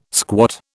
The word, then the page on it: squat: British English